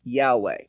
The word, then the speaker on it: Yahweh: His name is Yod Heh Vav Heh, often pronounced Yahweh.